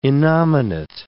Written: innominate

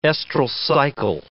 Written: estral cycle

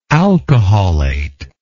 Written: alcoholate

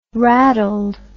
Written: raddled

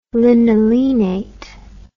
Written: linolenate

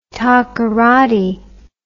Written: takoradi

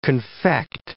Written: confect

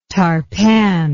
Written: tarpan

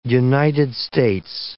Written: United States

